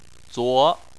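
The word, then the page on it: zuo: tone 2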